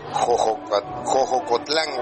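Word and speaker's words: Xoxocotlán: Ya me dijeron que se pronuncia Xochocotlán, Oaxaca.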